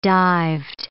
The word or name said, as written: dived